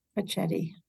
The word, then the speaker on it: Cecchetti: Roy Cecchetti. – Cecchetti. – No. You know what? We could leave this in because nobody knows, but it's cha as in Cecchi and ca as in radicchio. – Cecchetti.